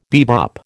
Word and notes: bebop: American English

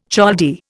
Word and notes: Chaldee: Indian

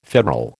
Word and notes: federal: American English

